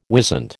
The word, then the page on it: wisent: American English